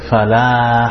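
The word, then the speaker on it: falah (فلاح): Which one was right? The second one. Because this is, where is this ha from? Which part? Middle of the throat. So we pronounce it falah.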